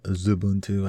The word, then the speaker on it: Xubuntu: So you have Ubuntu, Xubuntu, or actually it's supposed to be called Zubuntu, I guess.